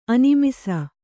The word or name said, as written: ಅನಿಮಿಸ